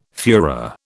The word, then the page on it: fuehrer: British English